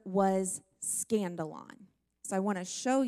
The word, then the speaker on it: skandalon: the word offended comes from the Greek word skandalon. That's a funny sounding word, but it's spelled like this if you're taking notes, S-K-A-N-D-A-L-O-N, skandalon.